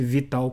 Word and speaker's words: V.tal: É, o nome é Vital, escreve-se V.tal, mas é, pronuncia-se Vital como se houvesse um I ali.